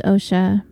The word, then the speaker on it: OCHSA: It is pronounced OSHA because it is formerly known as the Orange County High School of the Arts, or OCHSA, OSHA, but has since included the middle school level grades, so they took out the H and the pronunciation stuck.